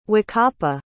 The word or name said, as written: wakapa